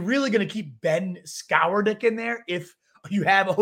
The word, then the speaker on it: Skowronek: I think they even said some Ben Skonowski or something like that. How do you pronounce dude's name? Skonowski? Skowronek? – Ben Skowronek. – Skowronek. That's what it is.